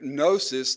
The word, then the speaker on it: gnosis: You know anything about Greek at all, you would understand the word gnosis there, the G is silent, is a word that means knowledge.